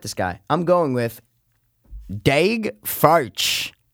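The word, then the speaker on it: Daeg Faerch: I swear it's D-A-E-G. – Oh, it's like Dag. – F-A-E-R-C-H. – Furch. Dag Furch. That's how you say it.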